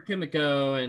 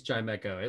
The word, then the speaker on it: Chimecho: No shiny Chimichus. – Chimechos. Either Chimecho or Chimico, and I think it's Chimecho. It looks like Chimecho.